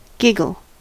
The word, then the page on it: giggle: American English